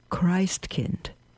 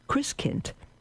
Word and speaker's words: Christkind: coming of Christkind, pronounced Christkind, rather Christkind, under mother's watchful eye, we'd fashion a small makeshift manger that would remain unoccupied until Christmas Day.